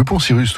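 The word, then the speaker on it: Poncirus: Alors, est-ce que je prononce bien le poncyrus ? – Le poncyrus, tout à fait.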